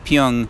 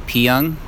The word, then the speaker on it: Pyun: as Tonight Is What It Means To Be Pyun, so I guess that's how you pronounce his last name.